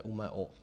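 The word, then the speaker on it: Umeå: O, takový to písmenko, to A s tím kroužkem, co většina lidí asi neví, jak se vyslovuje, tak to je O, takže to je Umeo.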